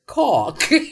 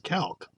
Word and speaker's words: calque: And the word is caulk. I tried to say it like you guys. – Yeah. – Caulk. Okay. – Caulk. – It's actually pronounced calc.